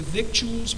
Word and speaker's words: victuals: And they took all the goods of Sodom and Gomorrah, and all their victuals, and by the way, that is the right pronunciation, victuals. Phonetically, we'd say victuals, but if you look it up in the dictionary, it's actually pronounced victuals.